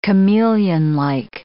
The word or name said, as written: chameleonlike